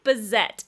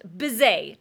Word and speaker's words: Bizet: It looks like Bizet if you saw it spelled, but he's a French composer and you pronounce it Bizet.